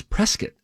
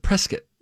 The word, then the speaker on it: Prescott: It is Prescott. – Oh, Prescott. – Pronounce it Prescott.